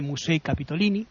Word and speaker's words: Musei Capitolini: Bueno, los museos capitolinos, que sabéis que en italiano se dice Musei Capitolini.